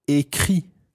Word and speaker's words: écrit: Now, écrit, this time, is spelled I-acute, C-R-I-T. Doesn't finish in an S this time, but a T. J'ai écrit.